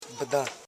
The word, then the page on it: bidan: bi.dan